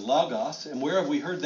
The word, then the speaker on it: logos: It's not logos. That's our English coming at it. It's logos. That letter O in the Greek is more of an A.